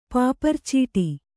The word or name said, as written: ಪಾಪರ್‍ಚೀಟಿ